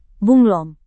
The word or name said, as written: bunglon